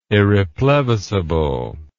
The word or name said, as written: irreplevisable